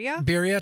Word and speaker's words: birria: Okay, well, the geese that I breasted out after our hunt, I made, I might pronounce this wrong, birria? – Birria tacos.